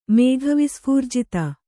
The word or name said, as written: ಮೇಘವಿಸ್ಫೂರ್ಜಿತ